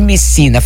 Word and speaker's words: Messina: pós-doutorando em Direito pela Universidade degli Studi di Messina, falei certo? – Isso, falou certo.